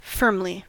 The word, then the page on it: firmly: American English